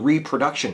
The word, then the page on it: reproduction: American English